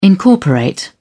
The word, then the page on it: incorporate: British English